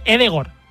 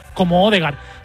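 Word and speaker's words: Ødegaard: Por ejemplo, Odegaard se dice Edegor, pero todos, ¿sí?, le conocemos, claro, todos le conocemos como Odegaard.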